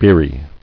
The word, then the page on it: Beery: beer·y